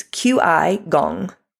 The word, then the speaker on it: Qigong: But at the age of 60, she found Qi Zheng, Qigong. I don't want to say that wrong. It's Qigong, G-O-N-G.